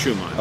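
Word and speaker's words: Schuman: They're both pronounced Schumann.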